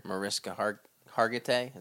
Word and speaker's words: Mariska Hargitay: Mariska Hargitay.